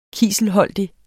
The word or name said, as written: kiselholdig